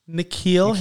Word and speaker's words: N'Keal: I've talked about Keneal Harry. N'Keal Harry. – Can't even get his name right.